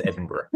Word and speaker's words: Edinburgh: No American can pronounce it, but it's Edinburgh. – Oh, that's right. I tried to practice. I knew that because I heard you say it, and I tried to practice it. – My wife, who's from Florida, I have to say, you have to remember it's spelled E-D-I-N and then B-R-R-R-R-R.